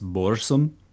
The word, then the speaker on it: Burzum: So other bands that were important to the scene were Burzum, Dark Throne, Emperor, Immortal, and a band called Thorns. – I believe from Metal A Headbanger's Journey, I believe it's pronounced Borsum.